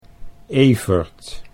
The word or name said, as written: Evert